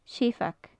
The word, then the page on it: seabhag: gaelic